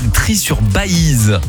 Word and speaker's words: Trie-sur-Baïse: Et alors, dans une petite ville des Hautes-Pyrénées qui s'appelle Trie-sur-Baïse. Alors, ça s'écrit avec un I-tréma, je précise.